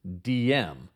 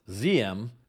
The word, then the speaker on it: Diem: Reading that name, I always thought it was pronounced DM, but after listening to many experts on Vietnam, apparently it was pronounced Ziem, so that's the way I will pronounce it.